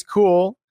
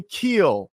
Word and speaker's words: Kuehl: So today, first, before I bring my guest on, yesterday I got a little excited and I mispronounced Justice's new last name. It used to be Enlow, obviously. And I said her last name was KUEHL. So on behalf of everybody, I apologize. It's actually KUEHL.